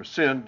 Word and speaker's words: syn: Sun, or pronounced sin usually, S-Y-N, also means with.